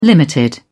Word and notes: limited: British English

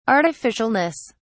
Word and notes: artificialness: British English

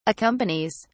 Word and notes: accompanies: British English

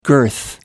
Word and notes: girth: American English

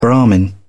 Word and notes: brahman: British English

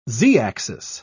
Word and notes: z-axis: British English

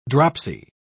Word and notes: dropsy: British English

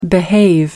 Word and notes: behave: American English

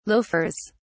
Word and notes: loafers: British English

